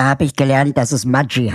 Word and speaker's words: Maggi: Da habe ich auch selber gelernt, dass Julius Maggi eigentlich ein Norditaliener, nee, ein Schweizer war er, glaube ich, aber mit norditalienischen Eltern und eigentlich Maggi ausgesprochen wird.